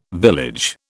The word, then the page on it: village: British English